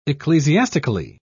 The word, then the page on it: ecclesiastically: British English